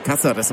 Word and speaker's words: Cáceres: Jede Aktion, auch die jetzt gerade von Cáceres, sie gerade über dem A ist ein Strich, also der Freund wird Cáceres ausgesprochen, gegen Cardiolo.